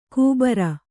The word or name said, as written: ಕೂಬರ